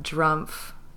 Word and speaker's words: Drumpf: Speaking of the elections, last time we were talking about Drumpf.